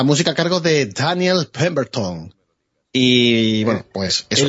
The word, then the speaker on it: Pemberton: Pemberton. – Ah, vale, entonces lo has dicho bien.